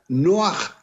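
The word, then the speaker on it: Noaj (נח): Noé se pronuncia Noaj en hebreo y se escribe con apenas dos letras, Nun, que vale 50, y Het, que vale 8.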